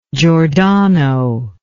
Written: giordano

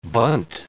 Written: Bundt

